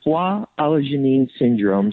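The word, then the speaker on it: Foix-Alajouanine syndrome: It's pronounced Foix Allogénine Syndrome, so it's a long French name,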